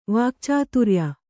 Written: ವಾಕ್ಚಾತುರ್ಯ

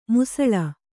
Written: ಮಾಸಾಳು

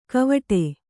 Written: ಗವೇಡು

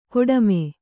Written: ಕಾಟಿಮೆ

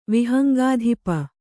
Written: ವಿಹಂಗಾಧಿಪ

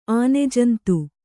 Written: ಆನೆಜಂತು

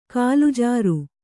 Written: ಕಾಲುಜಾರು

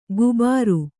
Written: ಗುಬಾರು

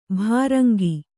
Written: ಭೃಂಗಿ

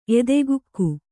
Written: ಎದೆಗುಕ್ಕು

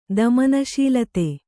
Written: ದಮನಶೀಲತೆ

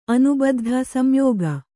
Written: ಅನಿಬದ್ಧ

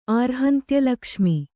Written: ಆರ್ಹಂತ್ಯಲಕ್ಷ್ಮಿ

